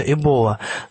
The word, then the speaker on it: Эбола: Посмотрев на Википедии, я обнаружил, что там ставится ударение на букву О, то есть произносится Эбола, но э, традиционно в России уже сложилось, что называется вирус Эбола.